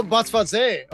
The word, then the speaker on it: Botsvadze: Oh, Botswate. Okay. Yeah. Oh, the Z is pronounced.